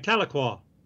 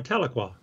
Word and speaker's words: Tahlequah: Well, you know, that's not how they say it. It's Tahlequah. – Mm-hmm. – You know, and just the way they say that, it's not Tahlequah, it's boring. It's Tahlequah.